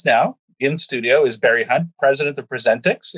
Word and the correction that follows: Prescient: And actually, we pronounce it Prescient. – Prescient.